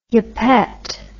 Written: yuppette

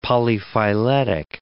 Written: polyphyletic

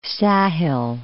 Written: Sahel

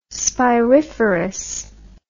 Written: spiriferous

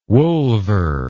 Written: wolver

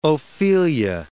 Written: Ophelia

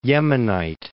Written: Yemenite

